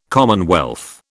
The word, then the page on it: commonwealth: British English